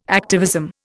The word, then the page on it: activism: Indian